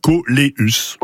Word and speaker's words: coléus: On prononce toutes les lettres en latin, coléus.